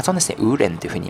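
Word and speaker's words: 无人: えこの 2 つ合わせてウーレンというふうに発音すると無人という意味になりますので、え今日はこちらの中国語とさせていただきます。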